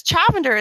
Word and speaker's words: Chavender: Yeah, so I tell you this because Chavender is spelt like lavender. – Okay. – Except a CH at the beginning.